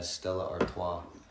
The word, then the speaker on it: Stella Artois: It's, yeah, Stella Artois.